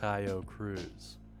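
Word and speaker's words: Taio Cruz: I always have to look up his name. It is pronounced Tayo Cruz.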